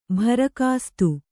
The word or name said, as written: ಭರಕಾಸ್ತು